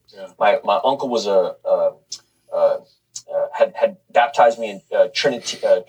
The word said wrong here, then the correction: Trinitarian: I'm saying it wrong. – Trinitarian formula.